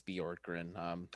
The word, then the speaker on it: Bjorkgren: So clearly it was not that much of an emphasis, but my apologies. It's Bjorkgren.